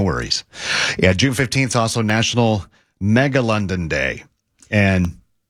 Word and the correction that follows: Megalodon: Megalodon. – Oh. It's like that's those are two completely different things. – No Megalodon and then a dinosaur.